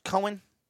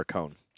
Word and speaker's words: Cohn: That's not pronounced Cohen. – He would prefer Cohn.